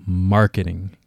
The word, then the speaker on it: marketing: Which syllable would the stress receive? The first one, second, or third? Okay, so it's going to be the first syllable, marketing.